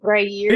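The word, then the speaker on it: Gruyère: How do you say it? Gray year. – There you go. Okay. – Is that the word? Is that how you say it? – Gruyere.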